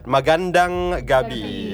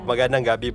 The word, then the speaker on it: Magandang gabi: Magandang gabi. Yeah, magandang po. Magandang gabi po.